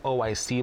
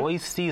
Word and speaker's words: Oishi: On the building, it looks like O-I-C Bowl, but mm-hmm. Oysi is the way it's pronounced, actually. Oysi being a play on words, it means delicious in Japanese, mm-hmm. Oishi. – But if you say O-I-C, you're okay.